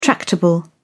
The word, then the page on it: tractable: British English